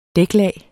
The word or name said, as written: dæklag